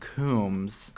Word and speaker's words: Coombs: And I learned a minute ago your last name is pronounced Coombs. – Correct. – Not like Sean Puffy Combs.